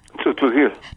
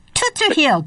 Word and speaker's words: Tzotzil: Tzotzil. – Tzotzil, got it.